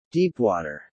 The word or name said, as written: deepwater